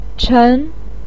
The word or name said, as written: chen2